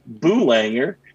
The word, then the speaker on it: Boolanger: And, you know, Bob from Rochester didn't really exactly know how to pronounce it. And it came out Boolanger.